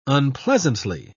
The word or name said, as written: unpleasantly